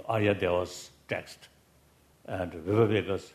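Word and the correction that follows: Bhavaviveka: Bhava Viveka. – Usually, no difficulty. Today, I found it a little difficult to pronounce. Kasa. – Bhava Viveka.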